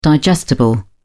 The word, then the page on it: digestible: British English